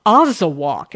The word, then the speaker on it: Azawakh: The Azawakh, and that's spelled A-Z-A-W-A-K-H.